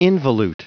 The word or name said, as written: involute